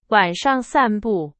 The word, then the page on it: 散步: Chinese